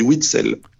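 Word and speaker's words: Witsel: est-ce qu'on dit Witzel ou Witzel on dit Witzel.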